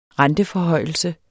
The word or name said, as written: renteforhøjelse